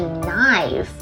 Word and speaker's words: knife: 首先，我们都知道刀子的英文是 knife 哦，它其中的 K-K-N-I-F-E 它是不发音的哦。